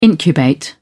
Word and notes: incubate: British English

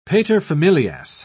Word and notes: paterfamilias: British English

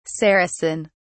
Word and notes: saracen: British English

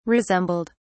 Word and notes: resembled: British English

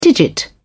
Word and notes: digit: British English

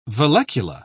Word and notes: vallecula: British English

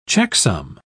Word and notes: checksum: British English

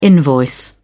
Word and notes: invoice: British English

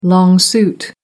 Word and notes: long suit: American English

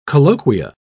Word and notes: colloquia: British English

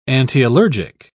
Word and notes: antiallergic: British English

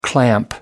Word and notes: clamp: American English

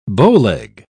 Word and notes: bowleg: British English

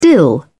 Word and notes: dill: British English

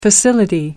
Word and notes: facility: American English

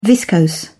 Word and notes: viscose: British English

